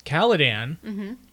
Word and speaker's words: Caladan: Caladan. – That's pretty good. – Caladan is...